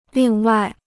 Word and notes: 另外: lìng wài

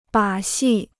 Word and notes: 把戏: bǎ xì

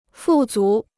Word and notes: 富足: fù zú

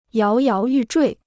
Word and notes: 摇摇欲坠: yáo yáo yù zhuì

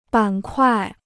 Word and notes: 板块: bǎn kuài

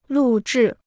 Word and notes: 录制: lù zhì

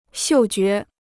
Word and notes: 嗅觉: xiù jué

